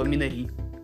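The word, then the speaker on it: «Минари» (미나리): Или, если вы кореец, то «Минари» на ударение на последний слог.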